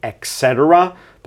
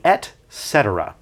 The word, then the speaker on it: et cetera: Sometimes people mispronounce it and say et cetera, but it's actually et cetera.